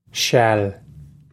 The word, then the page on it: seal: Irish